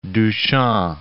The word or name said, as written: Duchamp